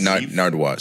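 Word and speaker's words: Nardwuar: I'm watching, is it pronounced Nardwar? – N-A-R-D. – W-U-A-R? – Yeah. – Yes. – Nardwar.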